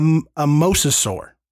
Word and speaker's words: mosasaur: But if you have, or if you've at least watched the trailer, you have seen at least, you know, a rendering of a mesosaur. – Mosasaur. – That. – You said it right. – Mosasaur, mesosaur. – You said it right the first time.